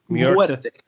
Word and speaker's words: Muerte: Now, feel free to tell me to pass on this, but Santa Muerte, am I saying that wrong? – Muerte.